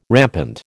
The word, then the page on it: rampant: American English